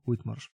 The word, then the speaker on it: Whitmarsh: Eee, także e, Martin, Wish, Martin Whitmarsh, dobrze wymawiam? Whitmarsh.